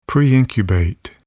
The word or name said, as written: preincubate